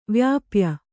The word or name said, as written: ವ್ಯಪಾಯಿ